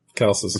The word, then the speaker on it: Chalcis: Chalcis.